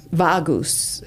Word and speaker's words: vagus: Vagus is how it's spelled, V-A-G-U-S.